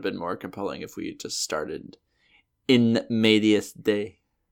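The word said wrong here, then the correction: in medias res: Is that you pronouncing in media res, but with a D instead of an R? – It's the accent.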